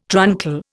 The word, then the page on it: truncal: Indian